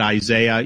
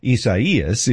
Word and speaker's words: Isaias: I don't know how to pronounce this because it's not normal. So, you know, I'm calling it Isaiah. You guys can call it what you want. I know that's not how you pronounce this. It's something else.